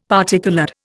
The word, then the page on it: particoloured: Indian